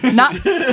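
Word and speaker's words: Nuyts: And same with the, I don't know how to pronounce it, Nuits or Noits or Nuts. Nuts.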